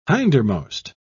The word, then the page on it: hindermost: British English